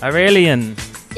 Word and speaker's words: Aureylian: Ori, Aureylian. – Our alien? It's our own personal alien? – Did I say it right? – Yeah. – Yeah. – Yeah, Aureylian. Mm-hmm. – Aureylian.